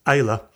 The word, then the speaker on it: Islay: Islay, pronounced Islay, has been one of Scotland's whiskey heartlands since at least the 1700s.